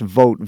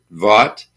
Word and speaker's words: Vogt: We did that on purpose because I was getting, announcers, DJs, etc., Vogt. – Oh, okay. – It was, right. Not clear how to pronounce the name, so... – Yeah, because it's V-O-G-T, so yeah.